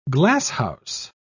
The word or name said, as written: glasshouse